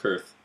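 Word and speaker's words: Qarth: And then uh, from there we go back to Karth. Jorah. Um, it's pronounced Kurth.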